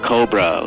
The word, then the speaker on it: Cobra: Cobra, like co-pay.